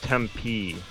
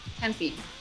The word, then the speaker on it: Tempe: The city in Arizona is pronounced Tempe. – Tempe. – Tempe. Yeah. – Tempe. – Mm. – It's not Tempe, so it's...